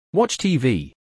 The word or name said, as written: Watch TV